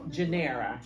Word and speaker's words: Genevra: Genevra. – Yeah, Genevra. Put it on here so I can say it right. Yeah, Genevra. The V is silent. I do not know why.